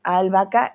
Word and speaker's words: albahaca: La H no se pronuncia, no decimos albahaca, no. No, no, no, por favor, ojo con esta H, es muda, ¿vale? Es muda, completamente muda. Decimos albahaca y como no hay diferencia de la B o de la V en español, pues se pronuncia igual, vaca, vaca, ¿ok?